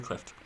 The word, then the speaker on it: Clift: Or Cliff, do you pronounce the T? Cliff? – Montgomery Clift.